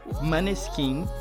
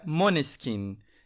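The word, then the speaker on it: Måneskin: Tá, acabei de ver um vídeo no YouTube que ensina a pronúncia e aparentemente é Moneskin.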